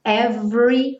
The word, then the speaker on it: every: Primeiro, aqueles es que não são pronunciados. Então, cuidado para não falar every time, every, como se fosse do V direto para o R, time, terminando no M.